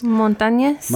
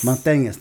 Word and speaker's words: Montañez: Montañez. Montañez, thank you.